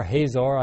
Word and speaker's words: Hazor: The king of Hazor, H-A-Z-O-R, Hazor, I don't know how you pronounce that, but I think the Hebrews pronounce it Hazor.